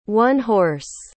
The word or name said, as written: one-horse